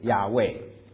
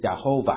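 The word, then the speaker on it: Yahweh: 那当然我说我想师母已经说过了，这个耶和华这个中文的翻译呢，更准确的翻译应该是，这个发音应该是亚卫，亚卫不是亚侯吧，那是过去的时候我们不太知道这个字该怎么发音，因为当时在旧约圣经的希伯来文它没有赋予这个母音。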